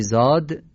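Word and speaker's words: ضاد: گفتیم زاد مثل زای دستداره، فقط سفته. زای دستدار نرمه ولی زاد سفته. در واقع فرق زاد و زا مثل غین و قافه.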